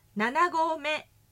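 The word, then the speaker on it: 七合目: あれは何と読みますか？ 7 合目と読みます。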